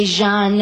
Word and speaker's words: Jean: Thus, Arthur, pronounce Jean, pronounce the French way Jean,